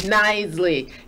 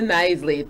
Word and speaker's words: Knisley: So, joining me today is a former classroom teacher and now real estate agent, Kina Kinsley. Is it Kinsley? – It's Knisley. – Knisley. You know, the silent K just kind of throws you. – Yes. – Kina Knisley, thank you so much for joining us at Crossroads in Learning.